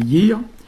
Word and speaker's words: yer: Yar qui donne Yir, hein, ou Yer, hein, ça s'écrit Yer, mais nous on dit Yir.